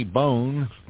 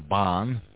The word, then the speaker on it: Bohn: Danny Bohn, or Bohn, B-O-H-N. You can pronounce that 2 ways.